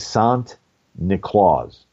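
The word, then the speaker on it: Sint Niklaas: The Dutch pronunciation of St. Nicholas is Sant Niklaus.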